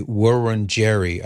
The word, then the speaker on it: Wurundjeri: the Wurundjeri, I believe is the pronunciation for this indigenous people.